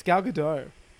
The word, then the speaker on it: Gal Gadot: It's Gal Gadot.